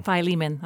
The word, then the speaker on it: Philemon: Philemon. That's better. – That's mm-hmm. how you say it.